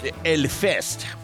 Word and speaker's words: Hellfest: Now then, if you are a really, really lucky person, you are going to probably be sitting in Hellfest. And that's pronounced Elfest by the French, okay?